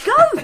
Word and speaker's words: Gove: saying Michael Gove's name is Michael Gove. It's Gove. it's spelled Gove.